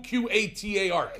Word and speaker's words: Qatar: Q Q A T A R.